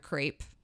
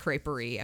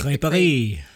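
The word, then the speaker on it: crêperie: The only thing I wish I would have done was had a crepe. Like I want to go to that creper. I'm sure oh. it's not crepe creperie. I'm sure it's like you pronounce it much better, but like creperie.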